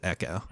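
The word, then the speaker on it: Ekko: It's spelled EKO, and they pronounce it Echo.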